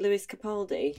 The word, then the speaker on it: Lewis Capaldi: You know, is it Lewis Capaldi? – Yeah. – Is that how you say his name? – Yeah, the Scottish guy, yeah.